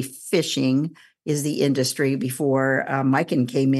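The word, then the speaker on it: Myken: But so some of the other distilleries are, I mentioned Det Norske Brenneri, Mikkel Distillery, and that's spelled D-I-S-T-I-L-L-E-R-I, and it's Mikkel with a Y, M-Y-K-E-N.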